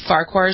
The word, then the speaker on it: Farquhar: A White Sox pitcher named Danny Farquhar, is that how you pronounce his name? – Yeah, this is really, yes you do, Farquhar is right, and this is a crazy, crazy story.